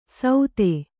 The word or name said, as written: ಸೌದೆ